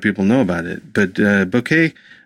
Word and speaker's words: Bokeh: Last time I tried to use Bokeh, I keep saying that wrong. You don't need to email me that I'm saying it wrong. I know it's Bokeh. It's Bokeh.